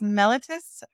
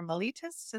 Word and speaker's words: mellitus: So diabetes mellitus or mellitus is what we're talking about. Mellitus or mellitus. I don't know. I think the British say mellitus.